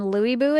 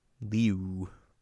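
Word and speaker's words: Lü Bu: Louis Boo, which I did check to see, like, is that pronunciation correct? And it's not. It's more correct than Lou, but like it's like a combination of the E and U sound somehow. – Liu. – Liu, which is different than Louis.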